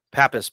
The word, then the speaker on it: Pappas: Am I saying that right? – Pappas, but close enough. – Pappas, sorry.